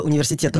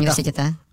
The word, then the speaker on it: университета: Университета? Нет, университета. – Ну, вы сами не знаете, я смотрю. – Ну, ничего. Есть двойное, возможно, ударение, даже тройное. – Хорошо. Совместная работа... Совместная работа астрономов Японского университета... – Университета, я бы все-таки сказал.